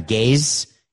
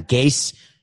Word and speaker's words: Gase: I thought it was Adam Gase, as in I'm gazing into the future, but I'm hearing that it's Adam Gase that rhymes with case, and it's spelled that way.